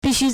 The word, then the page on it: 必须: bìxū